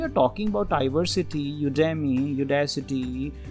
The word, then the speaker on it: Udacity: There is one more name that is called Udacity. Means I pronounce it that way. U-D-A-C-I-T-Y.